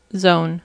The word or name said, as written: zone